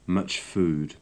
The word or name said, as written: much food